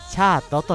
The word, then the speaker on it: SHEART: ここで 2 曲続けてお送りしたシャート、キドパープル、バイレデアイレについて簡単にご紹介したいと思います。まずは 1曲目のアーティスト、シャートについてご紹介いたします。アルファベット SHEART と書きましてシャートと読みます。